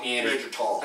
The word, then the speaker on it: Neanderthal: I think it's tall. Like tall. – Neanderthal. That's the right way to say it, but.